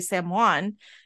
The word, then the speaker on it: Samhain: "It's actually Samhain."